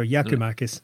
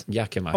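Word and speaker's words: Giakoumakis: I believe it's a soft G, so Yaku Macis. – Yaku Macis. – Almost like Jackie Mack, but yeah, the Greek Jackie Mack.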